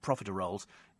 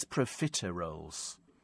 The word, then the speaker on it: profiteroles: It's not profiteroles, it's profiteroles. At least that's what we say in Northern Ireland.